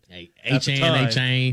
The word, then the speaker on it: Achane: At the time, Devin Achane. Changed his pronunciation, but Achane. – You're right.